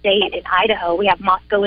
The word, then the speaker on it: Moscow: Well, it's Moscow, Idaho. – Moscow. – The locals. That's how we pronounce it here.